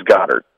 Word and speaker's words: Goedert: Is it Goedert? – I believe it's Goddard.